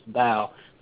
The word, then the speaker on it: Tao: It looks like it's spelled Taoist, but it's Tao.